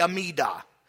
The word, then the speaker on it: Amida: You spell it A-M-I-D-A.